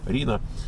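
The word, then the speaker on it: Reno: Пишется как Рено, да? R-I-N-O. Рино.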